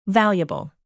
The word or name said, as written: valuable